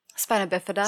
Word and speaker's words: spina bifida: So no, sp- am I saying it right? Spina bifida? Spine? Spina bifida. – Spina yeah. bifida.